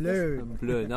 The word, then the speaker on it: Plön: We're in Plön. Is that how it's pronounced? Something like Plön.